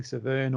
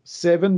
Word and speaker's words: Severn: I know that my English relatives pronounce it the Seven because it's named after the Seven River, and the, the Seven River and the Seven Valley in England, but I've always called it Severn.